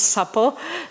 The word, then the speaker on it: SAPO: This SAPO, we pronounce SAPO,